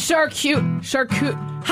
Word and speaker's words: charcuterie: No way. It's charcuterie. – Oh, my God. I can't. – There's no way I've been pronouncing this wrong my entire... It is charcuterie. How do you pronounce it? – Charcuterie. – Charcuterie? – Charcuterie. Yes.